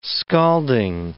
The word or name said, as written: scalding